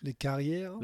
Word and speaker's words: Les Carrières: How do I say that? Les Carrières. – Les Carrières.